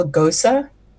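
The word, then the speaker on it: Gose: it's called a Gosa. It's spelled like goes, but it's called Gosa.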